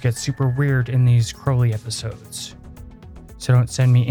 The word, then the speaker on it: Crowley: I only say Crowley because in his uh, he would often like rhyme his name in his poetry with holy and crowley rhymes with holy but crowley doesn't rhyme with holy